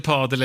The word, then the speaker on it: padel: Jag måste bara fråga, vad är det padel? – Ja, nu måste jag också fråga. Faktiskt. – Är det padel? – Ja. – Men enligt Saul så ska det uttalas med långt A.